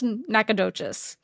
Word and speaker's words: Nacogdoches: You ready for my Spanish? Mm-hmm. Building the Mission Nuestra Señora de Guadalupe de los Nacogdoches. Got it. – You didn't butcher that one.